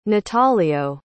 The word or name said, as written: natalio